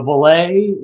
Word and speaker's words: Vole: And so Vole is how you pronounce it. Vole, it's a French word. We've always been an American company. We've always been USA made. We started in San Luis Obispo and are just south in Grover Beach, still in San Luis Obispo County. But it's a French name for an American company, and it's pronounced Vole, and it means to fly in French. So that is the official. So ole, you know, ole, ole, or vole. So vole.